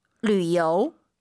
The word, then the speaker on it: 旅游: 旅游. – 유땡땡, 류. 류라 발음합니다.